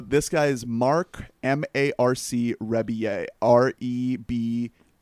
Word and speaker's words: Rebillet: Last time I talked about this guy, I pronounced his name Rebillet. But he pronounces that Rebillet.